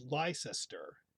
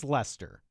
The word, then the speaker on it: Leicester: It's spelled, it looks like it's spelled Leicester, L-E-I-C-E-S-T-E-R. – I think it's Lester. – Right, it is.